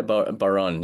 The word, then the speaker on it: Bodhrán: So you played accompaniment on the, I can't pronounce this, but Bodhrán? – Baron, But Baron, okay.